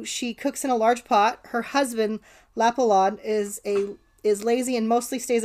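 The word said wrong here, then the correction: Leppalúði: I think that's pronounced Lepaludi, actually.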